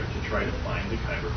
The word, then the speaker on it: Kyber: Now, how is it pronounced? – Kyber Crystal. – Kyber Crystal, okay.